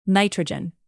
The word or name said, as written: nitrogen